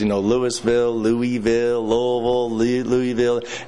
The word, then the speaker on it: Louisville: And it's not, you got to pronounce it like you got a mouthful of marbles, Louisville.